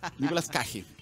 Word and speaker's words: Nicolas Cage: ¿Cómo se ah. pronuncia? ¿Cage? – Cage. Cage. Nicolas Cage.